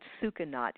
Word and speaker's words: Sucanat: It's called, well, I call it Sucanat. It's spelled S-U-C-A-N-A-T.